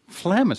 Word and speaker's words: phlegm: Phlegm is a pretty good, especially if it's got that G in there, that silent G, but you know it's in there, and you know it's going to come up hard when you say phlegmatic.